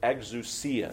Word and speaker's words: exousia: But the word that we find is this word, exousia, is how you say that.